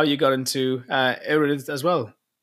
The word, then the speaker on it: Erudit: Erudit AI. Am I pronouncing that correctly, by the way? Is it Erudit? – Yeah, Erudit, whatever you want.